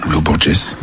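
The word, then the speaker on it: Lô Borges: Pour terminer cette playlist, Sofian Fanen avec Loborges. Je prononce très mal le portugais, pardon. Comment vous l'appelez, vous? – Loborges. – Bien sûr.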